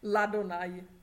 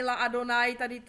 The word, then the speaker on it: Ladonaj: Jo, to je důležitý, takže to bude Ladonaj. Jo, není ani La Adonaj, tady je jakoby, je němý alef, jakoby, takže Ladonaj, jo?